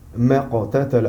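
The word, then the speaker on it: ما اقتتل: Alors, mais la prononciation ici, elle s'annule. Parce qu'on a le hamzat wasal. Donc lui, quand on a ce alif là, il est muet et il annule la prononciation qui le précède. Donc ما قتل.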